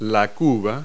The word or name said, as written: tub